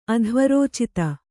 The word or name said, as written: ಅಧ್ವರೋಚಿತ